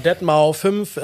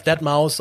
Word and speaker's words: Deadmau5: Deadmau 5, äh, Deadmau 5, Deadmaus ausgesprochen, wie, wie man auch mag.